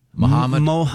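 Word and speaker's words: Mahomet: Is it pronounced Muhammad? – I believe so. – Muhammad.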